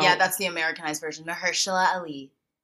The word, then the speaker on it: Mahershala: Mahershala is how the Southerns pronounced it. It's Mahershala.